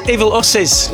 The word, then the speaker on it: Evil Usses: Is that how you pronounce this guy's name? – Evil Usses. – Evil Usses. – Like in Bill and Ted.